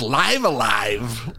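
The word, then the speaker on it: Live A Live: I believe it's pronounced Live Alive. Live Alive.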